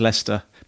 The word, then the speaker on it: Leicester: Leicester, which is actually pronounced Leicester, but it's spelt Leicester.